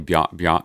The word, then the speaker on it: Bjarne: But since he has changed it again to, I can't pronounce it, Bjarni Skjernborg. – That's probably Bjarne. Like Bjorn.